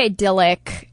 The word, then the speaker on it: idyllic: It was kind of like the idyllic, is that how you say it? – Yeah. – What is wrong with me in this show? This is embarrassing. The idyllic, neighborhood, you play with the kids in, you know, across the street.